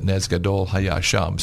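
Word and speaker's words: nez gadol hayasham (נס גדול היה שם): So the dreidel, this letter here, the nun, stands for the Hebrew word nez, which actually was the way we pronounce it, nez gadol hayasham.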